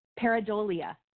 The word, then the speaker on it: Pareidolia: Pareidolia. Is yeah. – the tendency to interpret a vague stimulus as something such as seeing shapes in the clouds. – Pareidolia.